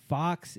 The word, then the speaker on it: Fuchs: Fox, he's German, F-U-C-H-S, Fox. But they pronounce it quick enough, so we all thought it was Fox, and eventually found out, oh, you're calling them Fox?